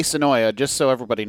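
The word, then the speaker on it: Senoia: Sonoy Raceway. I learned how to say Sonoy now. – Okay. – Finally, that's how the locals say it. The A is silent.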